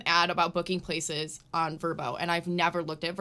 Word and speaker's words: Vrbo: I always used Vrbo, which I always called VRBO. I just found out recently, not how you pronounce it.